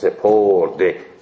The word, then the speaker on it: سپرد: خب خیر، پس ماضی در حال ماضی با او تلفظ میشه، بسپرد، بشمرد، شمرد، سپرد.